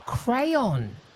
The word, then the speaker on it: crayon: Crown, I still can't pronounce it. Crown as in the one you draw with, and crown as in the one you put on your head. – Oh, crayon. Crayon, yes.